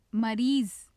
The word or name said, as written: mariz